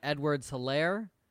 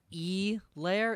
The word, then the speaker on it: Edwards-Hilaire: Clyde Edwards-Hilaire, thank you, Joe Buck, for pronouncing his last name correctly, not E Lair.